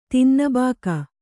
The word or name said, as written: ತಿನ್ನಬಾಕ